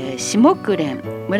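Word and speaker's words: 紫木蓮: 私は木蓮というのはねよく聞いておりましたけれども、サラサ木蓮っていうのを、ああそういう木蓮もあるんだなと思ってちょっと調べてみたんですけれども、シモクレン、紫の木蓮と書いてね、シモクレンと白木蓮、白い木蓮と書いて白木蓮の交配によって生まれた品種で、それをサラサ木蓮と言います。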